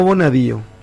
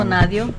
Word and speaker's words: Bonadío: ¿Cómo se pronuncia? ¿Bonadio o Bonadío? El juez argentino. – Bonadío. – Bueno, esto va para mis compañeros de la mañana temprano. – ¿Qué pasó? ¿Va presa? – ¿Quién dice perdón. Bonadio? Siempre fue Bonadío.